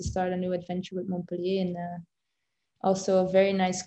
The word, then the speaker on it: Montpellier: Montpellier.